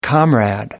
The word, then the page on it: comrade: American English